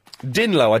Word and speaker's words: dinlo: All right, well, what about dinlo? – Dinlo, I don't know dinlo. – Yeah, well, din for dim, although you pronounce it din, yeah.